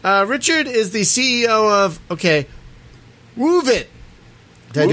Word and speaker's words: Woovit: You gotta add the, the emphasis at the beginning. Woovit.